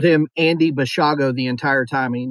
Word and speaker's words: Basiago: Now, his last name is spelled B A S I A G O. I've seen people pronounce it Basiago. I'm going with Bashago because he did an interview with Art Bell, who's pretty well known for this kind of paranormal time travel kind of stuff.